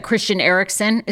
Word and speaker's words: Christian Eriksen: Obviously, the big news was that Christian Eriksen, is that, am I saying his name right? – Mm-hmm.